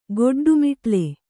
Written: ಗೊಡ್ಡುಮಿಟ್ಲೆ

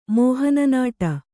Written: ಮೋಹನನಾಟ

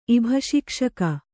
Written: ಇಭಶಿಕ್ಷಕ

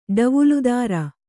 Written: ಡವುಲುದಾರ

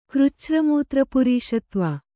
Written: ಕೃಚ್ಛ್ರಮೂತ್ರಪುರೀಷತ್ವ

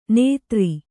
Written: ನೇತ್ರಿ

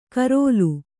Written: ಕರೋಲು

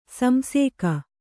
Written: ಸಂಸೇಕ

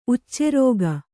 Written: ಉಚ್ಚೆರೋಗ

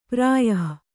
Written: ಪ್ರಾಯಃ

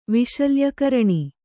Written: ವಿಶಲ್ಯಕರಣಿ

